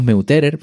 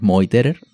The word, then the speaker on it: Meuterer: Vamos, decimos Meuterer, pero en alemán es Moiterer, La pronunciación.